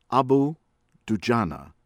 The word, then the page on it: Abu Dujana: American English; from Indonesia